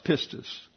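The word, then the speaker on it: pistis (πίστις): There are two syllables in pistis. They are named. This is the ultima right here, last syllable. And the second one is the penult syllable, and it's accented. And you see the accent there gives emphasis, obviously, on that syllable. So it's pronounced pistis.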